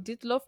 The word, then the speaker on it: Dyatlov: Yeah, that's the story of Dyatlov Pass.